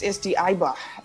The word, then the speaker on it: Eibar: Yeah, it's SD Eibar.